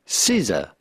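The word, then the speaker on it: SZA: We are looking at how to pronounce the name of this American singer and songwriter whose name is not particularly obvious to guess the pronunciation of if you just look at how it's spelled, S-Z-E. S-Z-A. How do you go about pronouncing this? Well, it's pretty straightforward once you know. Caesar. Caesar. Caesar. Caesar. Pretty easy. Caesar. That's it.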